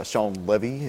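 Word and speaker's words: Shawn Levy: Yeah, so this was directed by Sean Levy. Is that? – Yes, that is right. – I got the pronunciation right on that one.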